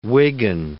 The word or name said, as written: Wiggin